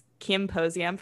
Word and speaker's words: Kimposium: It's uh so it rhymes with symposium. It's Kimposium for Kim Kardashian.